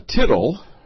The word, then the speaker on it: tittle: A tittle, which rhymes with little, is Greek keraia, which means literally horn, projection, hook, as part of a letter, a serif.